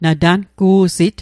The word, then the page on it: natangu'sit: Mi'gmaq